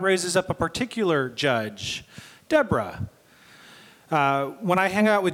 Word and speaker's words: Deborah: Let's call you Deborah. And it turns out in Hebrew, that's the more correct way to pronounce it.